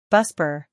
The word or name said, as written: BuSpar